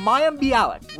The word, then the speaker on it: Mayim Bialik: I happen to admire and enjoy the work of M- Mayam B. I can never say I can never say her name correctly. I happen to admire and enjoy the work of Mayim Bialik.